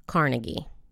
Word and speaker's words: Carnegie: The first thing that I should mention is his last name might actually be pronounced Carnegie. – Okay. – Because they're not related. – Oh, you guys. – All right. – And the reason that the other guy, Andrew Carnegie, his name is pronounced that way because it's a Scottish name. It's a Scottish pronunciation. Whereas Dale Carnegie probably changed his name to the Carnegie spelling later in life.